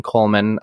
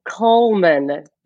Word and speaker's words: Cullman: So I know you grew up in Cullman, Alabama. Am I saying it right? – You are.